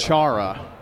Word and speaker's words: Kuchara: What is the correct way to Kuchara. – Say it? Kuchara. – Yeah, emphasis on the first syllable, right?